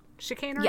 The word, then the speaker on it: chicanery: Chicanery? – Yeah. – I'm saying that right? – I think it's chicanery, yeah.